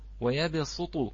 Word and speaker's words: ويبسط: ويبسط، نعم، ترسم صادا وتكتب، عفوا، نعم تكتب صادا وتلفظ سينا، نعم وتلفظ سينا، أحسنتم، نعم بارك الله فيكم. إذن الصاد تكتب وتقرأ سينا.